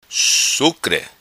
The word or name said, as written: Sucre